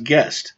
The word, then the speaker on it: Gist: His last name it's spelled G-I-S-T, but it's pronounced Guest.